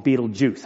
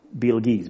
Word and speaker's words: Betelgeuse: Now, there's a couple pronunciations. Most people call it Betelgeuse, apparently, or Betelgeuse. But anyways, it's easier to say Betelgeuse.